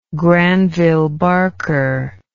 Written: Granville Barker

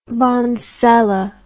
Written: bonsela

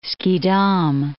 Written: Schiedam

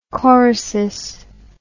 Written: chorisis

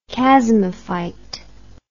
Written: chasmophyte

